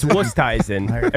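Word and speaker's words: Oosthuizen: It's Wusthuizen. Everybody says Wusthuizen, but they're actually wrong. – Wusthuizen.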